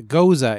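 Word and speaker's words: Gose: Goza is how you pronounce it.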